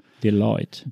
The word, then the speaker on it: Deloitte: Das schreibt sich ja französisch, spricht sich aber tatsächlich englisch-amerikanisch aus. – Deloitte.